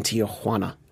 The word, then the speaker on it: Tijuana: Did you see that that was the reaction in Tijuana? – Yeah, I see it.